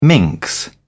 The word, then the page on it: minx: British English